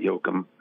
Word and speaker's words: Yoakam: Well, first of all, you pronounced it wrong. It's Dwight Yoakam.